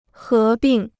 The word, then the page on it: 合并: hé bìng